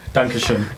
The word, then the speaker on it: Dankeschön: Dankeschön.